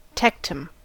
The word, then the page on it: tectum: American English